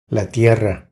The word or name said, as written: La tierra